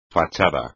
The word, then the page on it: fachada: espagnol